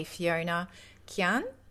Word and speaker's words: Fiona Qian: And I have the lovely Fiona Qian.